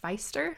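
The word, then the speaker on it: Pfister: And that book is Rainbow Fish by Marcus, I think you say his last name, Feister? It starts with a P, so it's an odd pronunciation.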